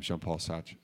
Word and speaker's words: Jean-Paul Sartre: Yes, and others that, Sartre, Jean-Paul Sartre, if I'm pronouncing that halfway correct. – You're very good. No, that was excellent.